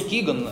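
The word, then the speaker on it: Kegan: По-русски Киган, да, но вообще по-английски пишется Кеган.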